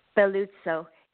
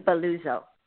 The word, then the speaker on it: Belluccio: Dr. Charlene Belluccio. If you say it more in English, it would be Belluccio.